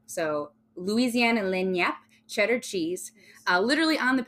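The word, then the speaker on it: lagniappe: It's L-A-G-N-I-A-P-P-E. How do you pronounce that, Keely? Lenyap. See? It wasn't even fucking close.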